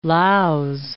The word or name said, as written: Laos